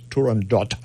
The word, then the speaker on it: Turandot: Now, I know you're heading for the phone to correct my pronunciation, but in fact, that is the way it should be pronounced, as Turandot.